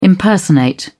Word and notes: impersonate: British English

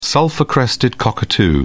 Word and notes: sulphur-crested cockatoo: British English

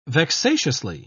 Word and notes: vexatiously: British English

